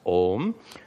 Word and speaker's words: Om: It's only pronounced Om.